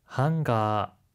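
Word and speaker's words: 한가 (ハンガー): 하이, 한가는 옷걸이를 뜻하는 영어 행거의 일본어식 발음인데요. 끝에 자음에 주의해주세요.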